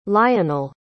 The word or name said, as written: lionel